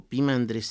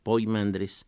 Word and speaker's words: Poimandres: Entonces, Poimandres o Pimandres, si pronuncio de las dos maneras es porque está escrito Poimandres, pero la forma habitual, porque parece que así era el sonido que tenía el griego en aquella época, ya no es Poi sino Pi.